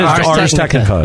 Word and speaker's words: Ars Technica: So this was actually an editorial that I had found from, and forgive me if I'm pronouncing this name of this website. Is it Ars Technica? Is that right? – It is Ars Technica.